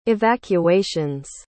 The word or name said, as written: evacuations